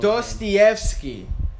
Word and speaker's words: Dostoevsky: But you don't know Dostoevsky.